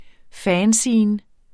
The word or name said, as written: fanzine